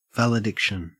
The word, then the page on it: valediction: Australian English